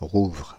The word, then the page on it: rouvrent: France (Île-de-France); /ʁuvʁ/